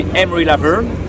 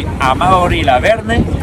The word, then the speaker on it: Amaury Lavernhe: Yeah, so on the English accent, it will be Amaury Lavernhe. – Amaury. – In Spanish, it will be Amaury Lavernhe.